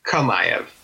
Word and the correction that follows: Chimaev: No Chimaev.